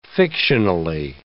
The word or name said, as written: fictionally